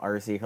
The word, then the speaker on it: Arce: Also, if you want to follow her on anything, it's at Danielle Arcee. Her last name is called A-R-C-E. I know people pronounce it like it's A-R-S-E. Forget that. A-R-C-E.